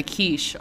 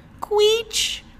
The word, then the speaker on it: quiche: Even today, she had a really funny one where we have a quiche on the menu, and she had a little old lady pronounce it, queech.